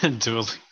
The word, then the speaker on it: Dua Lipa: Dua Lipa.